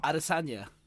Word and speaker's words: Adesanya: Adesanya.